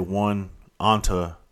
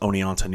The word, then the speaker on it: Oneonta: It's spelled differently. It's when my dumbass when I look at look at it, I want to say one onto, but it's actually pronounced Oneonta, New York.